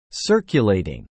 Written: circulating

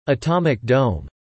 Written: Atomic Dome

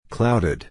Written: clouded

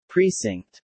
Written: precinct

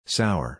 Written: sour

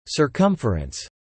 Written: Circumference

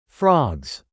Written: frogs

